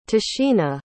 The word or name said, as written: tashina